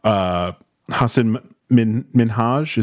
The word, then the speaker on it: Hasan Minhaj: Hasan Minhaj, is that how you say his name? – Yes.